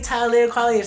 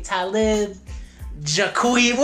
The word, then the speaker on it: Talib Kweli: But like this the paid actress, whatever, say, alright, say Talib Kweli. It's like, okay, Talib Jaqui. Where the hell do you get Jaqui? – For those who aren't familiar with this, Talib Kweli, Brooklyn-born rapper, Talib Kweli Green.